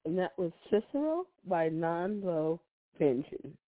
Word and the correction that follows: Nala Venge: Okay, I'm going to say his name is Nala Venge. – Oh, Nala Venge.